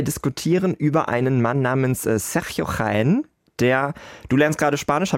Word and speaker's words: Sergio: Also das G, wenn dann I oder so kommt, da wird immer wie CH gesprochen und Okay. Also Sergio Jaén, der macht aktuell wohl die gefragtesten ESC-Stagings, unter anderem auch das von JJ.